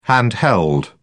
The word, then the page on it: handheld: British English